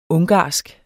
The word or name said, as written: ungarsk